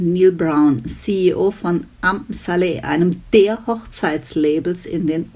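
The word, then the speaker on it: Amsale: Manche sagen auch Am Saleh, aber ich glaube, die korrekte Aussprache ist laut Wikipedia Am Saleh.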